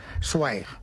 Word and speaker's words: Zweig: Zweig. – Esteban Zweig. – Es así como se pronuncia en alemán.